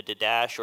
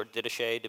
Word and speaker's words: Didache: It's in the Didache or Didache, depending on how you want to pronounce that. There's no right way.